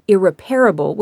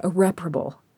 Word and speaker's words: irreparable: I realized I had trouble pronouncing the word irreparable. I kept saying irreparable, which, I mean, kind of makes sense. But yeah, irreparable.